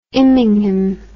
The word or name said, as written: immingham